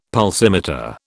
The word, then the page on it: pulsimeter: British English